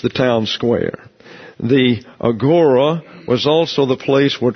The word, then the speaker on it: agora: It's not an omega, but an omicron, so it's agora. The last syllable is pronounced in Greek. So we would not say agora, we would say agora. But nobody's going to do that, so you don't fight City Hall. So it's agora to you.